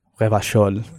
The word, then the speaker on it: Revachol: So Disco Elysium, a game set in a unique universe, taking place in the city of Revachol, that's how you pronounce it, in the borough of Martinet.